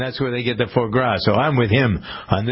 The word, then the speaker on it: foie gras: Foie, is it foie gras? How do you pronounce that? – Oh, foie gras.